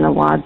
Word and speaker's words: Awad: It's Jason Awad's office.